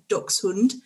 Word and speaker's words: Dachshund: Hunting, obviously, Dachshund proper pronunciation is Dachshund.